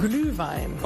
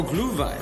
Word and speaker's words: Glühwein: Glühwein. – Oh, Glühwein.